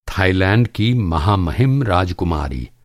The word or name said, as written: राजकुमारी